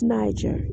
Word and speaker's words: Niger: But it's Niger, N-I-G-E-R, Niger. I don't know if people just didn't pronounce it correctly in the past, but it's one G, not two G's. And it's Naga, N-A-G-A.